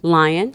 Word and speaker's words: Lyon: Lyon.